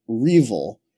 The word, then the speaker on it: REvil: It was R Evil, and it's spelled R-E-V-I-L. – And yeah, but some people pronounce it Revil.